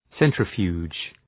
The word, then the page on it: centrifuge: ‘sentrə,fju:dʒ